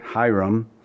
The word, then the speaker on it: Hiram: And Hiram, or literally it's pronounced Kiram, but we all say Hiram, king of Tyre, sent messengers to David and cedar trees and carpenters and masons, and they built David an house.